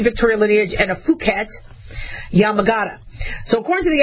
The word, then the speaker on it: Phuket: I know you want to do, you know, short U instead of long U, but it's Phuket, and that's a Yamagata lineage.